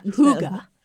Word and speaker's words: Hygge: Hygge, I think that's how you pronounce it. – Right? I just learned about that. Yeah. – Well, I heard about it last year, so it's already old hat. – Hygge is what I was told.